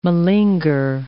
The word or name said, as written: malinger